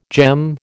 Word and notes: gem: American English

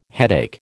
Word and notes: headache: American English

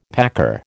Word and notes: packer: American English

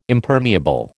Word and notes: Impermeable: American English

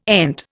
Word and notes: ENT: Indian

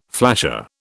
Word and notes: flasher: British English

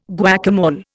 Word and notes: guacamole: Indian

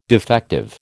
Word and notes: defective: American English

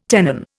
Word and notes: tenon: Indian

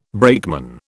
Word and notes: brakeman: British English

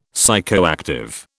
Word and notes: psychoactive: British English

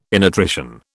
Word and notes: innutrition: British English

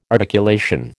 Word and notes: articulation: American English

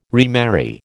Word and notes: remarry: American English

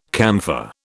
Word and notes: camphor: British English

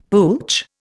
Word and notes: pooch: Indian

